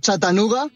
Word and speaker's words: Chattanooga: La ciutat se llama Chattanooga en el estado de Tennessee.